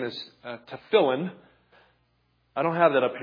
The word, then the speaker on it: tefillin: the Jews will refer to it nowadays as the tefillin, T-E-F-I-L-L-I-N, T-E-F-T-E-F-I-L-L-I-N, tefillin, and they'll put the emphasis on the middle syllable there, tefillin.